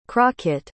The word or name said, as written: crockett